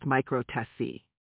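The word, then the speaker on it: micro-TESE: He underwent a surgical procedure called a microtease, pronounced microtesi.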